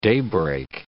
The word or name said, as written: daybreak